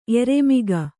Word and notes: ಎರೆಮಿಗ: kannada

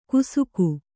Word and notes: ಕುಸುಕು: kannada

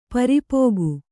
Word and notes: ಪರಿಪಾಕ: pari pāka

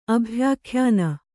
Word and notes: ಅಭ್ಯಾಖ್ಯಾನ: Kannada

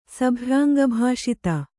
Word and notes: ಸಭ್ಯಾಂಗಭಾಷಿತ: Kannada

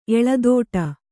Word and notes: ಎಳೆದಾಡು: eḷedāḍu

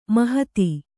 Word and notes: ಮಹತಿ: mahati

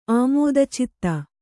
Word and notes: ಆಮೋದಚಿತ್ತೆ: Kannada

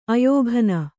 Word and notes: ಆಯ್ಕನೆ: āykane

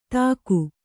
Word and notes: ಧಿಕು: dhiku

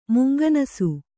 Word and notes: ಮುಂಗನಸು: munganasu

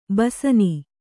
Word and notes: ಬೇಸಿನ್: Kannada

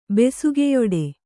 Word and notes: ಬೆಸುಗೆಯೊಡೆ: Kannada

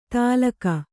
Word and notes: ತಾಲಕ: tālaka

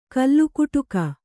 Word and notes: ಕಲ್ಲುಕುಟುಕ: kallukuṭuka

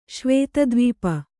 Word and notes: ಶ್ವೇತದ್ವೀಪ: Kannada

